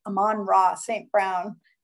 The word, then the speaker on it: Amon-Ra St. Brown: And I'm a big fan of the wide receiver they drafted in the fourth round, 112th overall pick, Amon-Ra St. Brown.